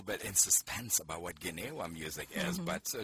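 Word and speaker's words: Gnawa: that something called Ginewa. – Yes. – Am I pronouncing that correctly? – Yes, you're pronouncing it correctly.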